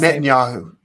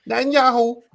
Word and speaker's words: Netanyahu: How do you pronounce his last Netanyahu. – Name? – Netanyahu.